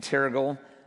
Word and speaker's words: Terrigal: drove down to his house in Terrigal, um, which every time I hear that, it just rhymes with terrible.